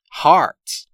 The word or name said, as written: hearts